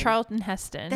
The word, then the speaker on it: Charlton Heston: Charlton Heston.